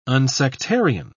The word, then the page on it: unsectarian: British English